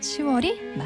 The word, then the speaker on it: 10월: 6월과 10월이 아니라 6월과 10월인 이유 바로 발음조 현상 때문인데요. 발음하기 쉽거나 듣기 좋도록 소리가 바뀌는 음운 현상을 말합니다. 이렇게 달라진 소리가 표준어로 굳어졌기 때문에 발음할 때도 표기할 때도 6월, 10월이 맞습니다.